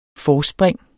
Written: forspring

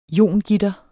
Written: iongitter

